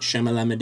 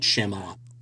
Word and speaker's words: Shyamalan: Shyamalan? – Yeah, M. Night Shyamalan Ding Dong. No, M. Night Shyamalan.